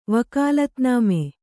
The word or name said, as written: ವಕಾಲತ್‍ನಾಮೆ